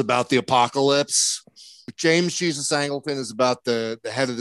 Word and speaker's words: Angleton: I mean, you've got the James Jesus Angleton. Is that how you say it? – Angleton.